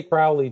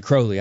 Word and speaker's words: Crowley: Well, I used to say Crowley too. I was one of the original. And then I read some of his stuff where he rhymes his name with holy. So right. – It's a tough habit to break. It took me a long time. – But that is how you say it correctly.